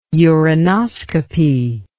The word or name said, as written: urinoscopy